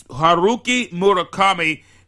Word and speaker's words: Haruki Murakami: That's right, it's Haruki Murakami. And for those who want to look it up, it's spelled H-A-R-U-K-I-M-U-R-A-K-A-M-I, Haruki Murakami.